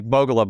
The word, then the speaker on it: Bogolub: It's like Bogolub, my last name. How do you spell it? Buy one, get one, L-U-B.